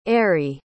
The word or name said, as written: Eiry